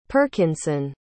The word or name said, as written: perkinson